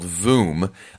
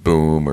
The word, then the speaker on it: Voom: Royal Caribbean offers something called Voom, V-O-O-M. Sometimes people mispronounce it boom or vroom or shroom. No, wait, that's something else. All things. Anyway, it's Voom.